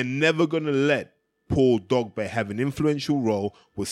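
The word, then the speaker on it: Dogboe: I should say Dogbe, that's really how they say it.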